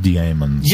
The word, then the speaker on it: Dæmons: In it's pronounced diamonds.